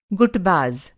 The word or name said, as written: गुटबाज़